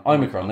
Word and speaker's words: Omicron: Forgive me if I pronounce this wrong. Is it the Omicron? Omicron. There you go.